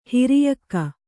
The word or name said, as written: ಹಿರಿಯಕ್ಕ